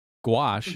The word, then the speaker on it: gouache: Gouache, mm-hmm. which rhymes with squash, which is a fruit.